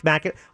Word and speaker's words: Mackinac: Oh, it's Mackinac. Well, if they want Mackinac Islands pronounced Mackinac, change that C to a W.